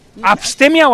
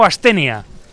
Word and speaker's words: astenia: ¿Abstemia primaveral? ¿Abstemia o astenia? – Astenia.